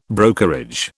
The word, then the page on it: brokerage: British English